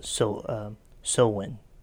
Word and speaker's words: Samhain: And we're talking about, as you said earlier, it's a Gaelic word, and it's some people will say Samhain or Samhain, but in several of the pronunciations that I read, it's called Samhain.